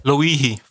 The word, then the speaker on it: Loihi: Zeg nog eens. Loihi, volgens mij. Ik wist niet hoe je het moest, moest uitspreken. Het stond op internet. Het was Hawaiiaans volgens mij. En Loihi is oh. wat erbij stond. Maar dat, ja, dat is wat ik... L-O-I-H-I in elk geval.